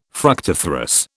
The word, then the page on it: fructiferous: British English